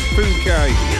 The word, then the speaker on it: Phuket: Let's not read it out the way it's spelt. We've not long started, have we? So, yeah, I think it's Phuket. We'll leave it at that.